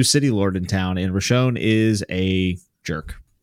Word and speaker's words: Roshone: And then the new city lord, Blightlord Roshone, how do you say it? – You got it, man. – Roshone, yeah.